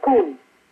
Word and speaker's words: pun: P-U-N. Pronounced Poon.